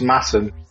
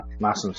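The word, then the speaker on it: Masham: But pronounced Massam because it's weird in Yorkshire. Imagine it's got two S's rather than an SH in it. Massam cider press. – So it's Massam, but spelt Mashem.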